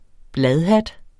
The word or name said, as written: bladhat